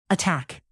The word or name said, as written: attack